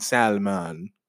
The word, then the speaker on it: salmon: There's no L in salmon. – Well, it is an L. – There's an L, but there's no in the pronunciation, nigga.